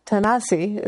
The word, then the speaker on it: Tanasi: The original pronunciation for Tennessee is Tanasi, is where they got the, the anglicized.